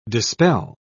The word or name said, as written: dispel